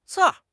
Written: 刺

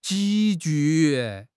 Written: 急剧

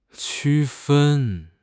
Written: 区分

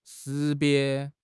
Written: 识别

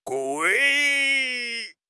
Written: 跪

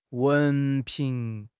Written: 文凭

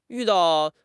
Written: yu4 dao4

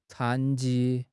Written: 残疾

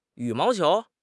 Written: yu3 mao2 qiu2

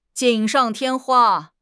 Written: 锦上添花

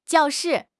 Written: jiao4 shi4

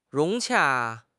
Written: 融洽

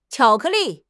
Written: qiao3 ke4 li4

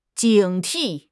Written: 警惕

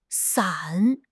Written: san3

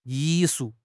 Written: yi4 shu4

